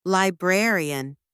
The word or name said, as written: librarian